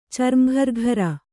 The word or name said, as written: ಚರ್ಮಘರ್ಘರ